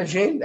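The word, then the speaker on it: Medellín: Now, I think I'm going to say this correctly. It's Medellin, right? Medellin Mets fan. – Medellin, that's good.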